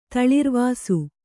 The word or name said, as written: ತಳಿರ್ವಾಸು